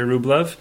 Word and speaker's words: Rublev: And so he was getting on my case for how badly I was pronouncing Andrei Rublev.